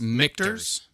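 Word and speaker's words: Michter's: It's Michters. – Michters? – Michters. – Yeah. – You know the English language is amazing when the CH that you've been taught your whole life sounds like a ch.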